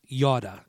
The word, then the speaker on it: yada: The word know, K-N-O-W, is pronounced yada. It's Y-A-D-A, yada.